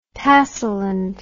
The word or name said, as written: tassyland